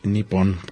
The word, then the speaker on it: Nippon: Significa, eh, para ellos Japón lo pronuncian, hijo, los caracteres son tremendos, pero es Nippon.